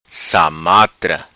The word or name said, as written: Samatra